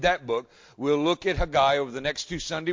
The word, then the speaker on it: Haggai: And as I said this morning, Haggai, or as we like to say it here in the South, Haggai, but it's Haggai in Hebrew. Remember, anytime you see doubled consonants, both of them are pronounced, and generally the second syllable takes the emphasis. So it's Haggai.